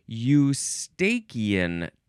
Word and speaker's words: eustachian: E-U-S-T-A-C-H-I-A-N. The next word is tube. T-U-B-E. Like a slide is a tube. You can also pronounce it eustachian tube. Some people like to say eustachian tube.